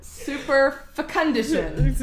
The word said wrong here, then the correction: superfecundation: Super fecundition. – That's probably wrong. – Super fecundation. It's spelled super fecundation.